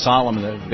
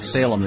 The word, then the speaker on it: Salem: And in the fall of 1978, started the New England, it's pronounced Salem, based on a German program, but it's spelled like Salem, the New England Salem Children's Village.